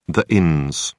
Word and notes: inns: British English

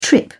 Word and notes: trip: British English

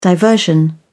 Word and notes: diversion: British English

